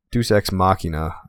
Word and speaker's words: Deus Ex Machina: Deus Ex Machina. That's how you pronounce that.